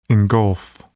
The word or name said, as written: engulf